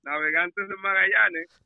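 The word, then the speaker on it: Navegantes del Magallanes: Navegantes del Magallanes.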